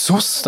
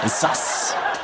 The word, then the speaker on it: sus: Das nächste Jugendwort-Anwärter des Jahres erklären wir dann um 7:45, dann ist das Wort, das habe ich gestern schon falsch ausgesprochen, wie die Schulführung da war, ich habe gesagt Sus, dann haben alle gelacht. Es heißt nämlich Sass.